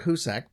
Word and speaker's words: Hoosac: Right off the bat, there was confusion because I wanted to make sure we pronounced it right. So I looked up 4 or 5 different stories. A couple said Hoosac. Couple said Hoosac. And We're going to go with Husak because it was the news people who actually said Husak.